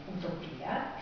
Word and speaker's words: eutopia: Sempre in inglese però esiste una seconda dizione del termine utopia, si pronuncia nello stesso modo, utopia, ma è scritto in questo modo, eutopia, dove appunto, sempre dal greco, eu buono, bene, e topos nuovo, quindi buon luogo.